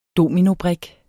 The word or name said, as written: DOMINOBRIK